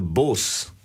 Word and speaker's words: Bose: But the Bose is spelled B-O-S-E after Subhas Chandra Bose, okay?